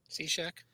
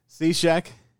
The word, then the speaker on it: Cishek: Cishek. – Cishek.